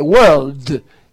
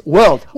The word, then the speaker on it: world: Es, una es word, word, y la otra tiene una L, world. Es world.